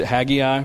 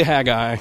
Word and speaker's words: Haggai: But we're going to we're going to spend tonight and next week in the book of, of Haggai, which I always pronounce it Haggai, but I think that's because I'm from Central and we kind of pronounce things weird. It's technically Haggai, uh, so hang in there with me and don't correct me in your mind every time I say it.